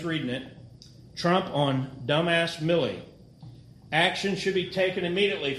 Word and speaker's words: Milley: Is his name pronounced Miley or Millie? – Millie.